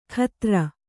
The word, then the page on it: ಖತ್ರ: khatra